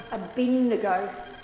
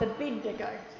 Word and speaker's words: Abednego: I thought it was Abednego. – Yeah. – Abednego. So, yeah, so I'm going to get it wrong. So it's Abednego. To Abed you go.